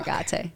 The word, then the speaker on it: Agate: I think you know it's Sara Agate.